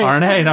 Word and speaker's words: Arne: Arne? Arne? Is that right? – That's right. – Arne, all right.